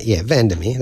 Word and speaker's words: VanderMeer: Annihilation by Jan Vandermeer, Vandermeer, that's how you pronounce it.